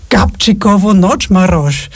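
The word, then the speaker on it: Gabčíkovo-Nagymaros: Und in dem Moment war ich so auf Gabchikovo Nochmarosch. Und ich weiß noch, wie Shearer sich umdreht und mich anschaut und sagt, oh, that's how you pronounce it.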